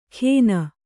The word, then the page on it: ಖೇನ: Kannada